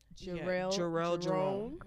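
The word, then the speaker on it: Jharrel Jerome: Jarrell Jerome.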